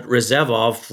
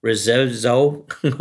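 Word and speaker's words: Rzeszów: And from Rezevov, and I hope I'm saying that correctly, I'll pronounce it because I'm not the best. It's R-Z-E-S-Z-O-W, Rezevzow, okay? Forgive me for my Polish.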